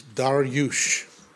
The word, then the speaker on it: Darius: His name is actually pronounced Dariush, and he had just become king after Belshazzar and the vision of the writing on the wall.